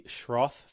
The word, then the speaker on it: Schroth: You mentioned the Schroth therapy. Did I say that right? – Yes, yeah.